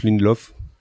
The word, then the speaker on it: Lindelof: avec Damon Lindelof je sais pas comment ça se prononce Lindelof c'est bien, oui.